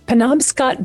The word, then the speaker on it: Penobscot: Gregory Brown grew up along Penobscot Bay.